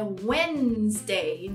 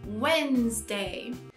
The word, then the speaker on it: Wednesday: Muita gente conhece que o D é mudo na quarta-feira, mas vamos lá praticar a pronúncia junto. Apesar de ser escrito Wednesday, não se fala assim. É Wednesday, direto no N.